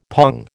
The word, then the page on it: pong: American English